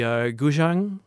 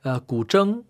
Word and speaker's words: guzheng: And then the guzheng, mm-hmm. – How do you pronounce it? – Guzheng. – Guzheng.